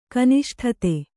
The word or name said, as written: ಕನಿಷ್ಠತೆ